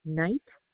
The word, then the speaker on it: Knight: Okay, name on the order would be Joan Knight, K-N-I-G-H-T. – Spell it, please. Spell the first and last name. – J-O-A-N, last name, K-N-I-G-H-T.